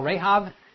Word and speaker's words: Rahab: We call Rahab in English. Rahab is how the Hebrews pronounce it.